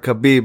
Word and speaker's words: Khabib: Like Habib said, or Khabib, my bad. – Khabib? – Yeah, I said it right, I think.